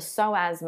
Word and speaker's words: psoas: the psoas muscle. There's a silent P in there if you want to Google it, P-S-O-A.